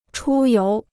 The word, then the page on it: 出游: chū yóu